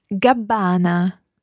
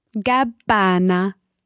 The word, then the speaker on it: Gabbana: And notice there, the Gabbana has got a double B but a single N. Listen to the pronunciation of Gabbana again. – Gabbana. Gabbana.